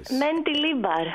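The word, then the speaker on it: Mendilibar: Να τα πούμε Μ' αρέσει όμως πόσο ωραία το λες το Μεντιλίμπαρ. – Μεντιλίμπαρ. – Έχει την βασκική προφορά.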